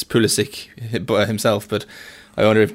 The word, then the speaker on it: Pulisic: I mean, it's what he says it is, because I mean, he's obviously of Croatian descent, so in Croatia it would be Pulisic or even Pulisic. I don't know if there's an accent on the S. – Yeah. – But you know, he's American, so he prefers an anglicised pronunciation.